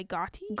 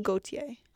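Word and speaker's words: Gotye: Cause how do you say that? By Gotti? – Gautier.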